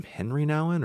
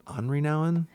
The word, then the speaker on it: Henri Nouwen: It's The Prodigal Son by, do you say his name Henry Nouwen or is it Henri Nouwen? – Henry Nouwen.